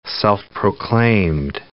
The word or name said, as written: self-proclaimed